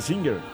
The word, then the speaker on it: Singer: Y la pronunciación oficial debía ser Singer.